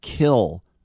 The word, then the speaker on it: Kiln: Remember, he's from the town of Kill, Mississippi, K I L N, and you don't pronounce the N, which is perfect for a guy who's some people pronounce the R in front of the, the V.